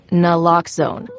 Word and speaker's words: Naloxone: For the record, it's Naloxone. Nuh, like, is Naloxone addictive? Nuh. Lox, like Naloxone keeps humans safe, like locks keep bikes safe. And own, like Naloxone can save lives in our very own communities.